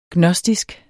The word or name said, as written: gnostisk